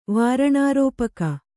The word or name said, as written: ವಾರಣಾರೋಪಕ